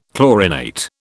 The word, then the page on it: chlorinate: British English